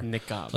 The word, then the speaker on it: niqab: Wait, H-I-J-A-B. – Yeah, this is something else, though. – Niqab. – Yeah, I said it right. Niqab.